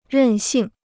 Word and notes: 任性: rèn xìng

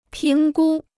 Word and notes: 评估: píng gū

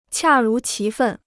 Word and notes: 恰如其分: qià rú qí fèn

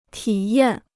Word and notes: 体验: tǐ yàn

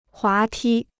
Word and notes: 滑梯: huá tī

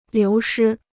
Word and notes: 流失: liú shī

